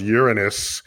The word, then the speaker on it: Uranus: I wish I could have been Uranus if you're like 8 years old. Yeah. – Exactly. – But when you reach 9 and you realize it's pronounced Uranus, generally you don't go back.